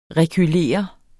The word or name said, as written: rekylere